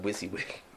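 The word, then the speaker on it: WYSIWYG: It's pronounced Wissy Wig.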